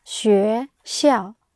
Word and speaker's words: 学校: 学校. – Yeah, 学校.